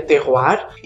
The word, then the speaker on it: terroir: É uma palavra de origem francesa, se escreve terroir, se pronuncia terroir, e terroir significa o um conjunto de três fatores: a mão do homem, o solo e o clima.